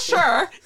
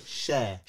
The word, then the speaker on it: Cher: It's not sure. – I can't, you know, the listeners will know now, the way I pronounce things is so off. It's that 7 months that I did in Nigeria that just fucks me up for life. So I really want to get it. Share.